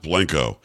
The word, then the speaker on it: Blanco: Now, clearly in Spanish, that's pronounced Blanco. – Mm-hmm. – In Italian, it's pronounced Blanco. In San Antonio, it's pronounced Blanco. Once you're told it's pronounced Blanco, you probably just go with it.